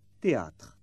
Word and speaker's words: théâtre: The combination TH is pronounced T, théâtre.